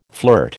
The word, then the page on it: flirt: American English